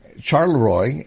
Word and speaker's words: Charleroi: This is a Charleroi, Pennsylvania, and I probably pronounced that wrong. It's Charleroi, and it's Pennsylvania, is a small blue-collar town with 4,000 residents that rely on factory jobs.